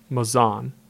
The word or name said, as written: Mazon